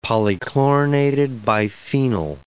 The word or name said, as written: polychlorinated biphenyl